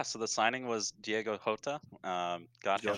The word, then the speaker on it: Jota: Pronounce the J, like Jose Mourinho, Diego Jota, Gabriel Jesus.